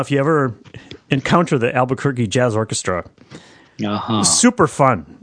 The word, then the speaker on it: Albuquerque: Albuquerque. – Albuquerque.